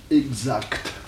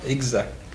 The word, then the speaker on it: exact: Oh, écoutez, actuellement, les deux prononciations existent. Vous ne pouvez pas dire que l'une soit bonne et que l'autre soit mauvaise, voyez-vous? Les deux existent, voyez-vous? Seulement, vous pouvez les juger d'une façon différente. L'ancienne prononciation, si vous voulez, l'ancienne, quelle est-elle? – Exa. – Exa. Voyez-vous, sans que te.